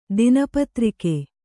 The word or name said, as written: ದಿನಪತ್ರಿಕೆ